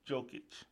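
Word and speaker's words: Jokić: Number 10, Nikola Jokic. Yes, I did say it right.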